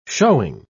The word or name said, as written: showing